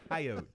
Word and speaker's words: coyote: Okay, how do you pronounce, what's the correct pronunciation of this? Coyote or coyote? – It's coyote.